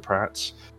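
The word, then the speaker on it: Praet: I tend to find myself pronouncing it Pret to avoid that, but I know it's pronounced Pratt.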